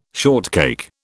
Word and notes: shortcake: British English

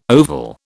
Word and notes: oval: British English

